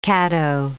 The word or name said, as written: Caddo